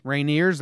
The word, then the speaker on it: Rainier: Rainiers. Rainiers, that's how you pronounce it? – Yeah. – There's a minor league baseball team called the Tacoma Rainiers.